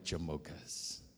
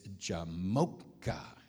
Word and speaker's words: Jamocha: How many of you like Jamochas? The second granddaughter, she couldn't say it very well, so we really worked on the pronunciation, and it's Jamocha, okay? And so she would repeat that with me, Jamocha.